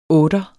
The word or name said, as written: otter